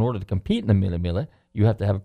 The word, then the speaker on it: Mille Miglia: Some people say Mille Miglia. The G is pronounced, and I think it's Mille Miglia.